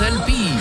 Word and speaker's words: LP: Ça se prononce LP.